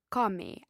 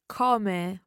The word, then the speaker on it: come: Is it better to say come or come? How do you pronounce the O? – You can pronounce it in both ways, come or come, and it varies depending where you are in Italy.